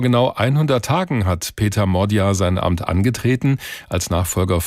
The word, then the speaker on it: Magyar: Er schreibt sich zwar Peter Magyar, also mit Y, spricht sich aber Modja aus.